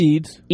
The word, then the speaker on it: Eads: E-A-D-S. – Eads.